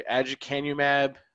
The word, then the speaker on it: aducanumab: Yeah, Biogen is planning to file for regulatory approval of their Alzheimer's drug, aducanumab, that was previously...